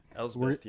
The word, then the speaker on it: Elspeth: But she did a really good job, especially in the introduction scene, of just going ham on the soldiers of Morgan Elspeth. I think that's how you say her name. – Elspeth.